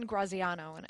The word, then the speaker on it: Graziano: You and I spoke about it before we started the show, and Dan Graziano, and I'm pronouncing it.